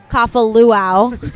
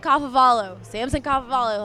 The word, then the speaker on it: Kafavalu: I was doing something for a show on campus called CU Sports Mac, but I pronounced it Samson Kafaluau. It was so bad, and I was just like, and right before I went on camera, I was like, Samson Kafavalu, like 50 times, and then went on camera and just said Kafaluau.